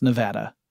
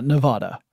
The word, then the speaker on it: Nevada: So I went to CES 2019 in Las Vegas, Nevada, which I have learned is pronounced Nevada. Not Nevada.